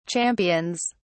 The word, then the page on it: champions: British English